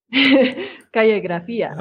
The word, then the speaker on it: Calle Grafia: His project, and you can correct my pronunciation, is Calle Grafia. Grafia? Okay, now say it right. – Calle Grafia. – Okay, there we go.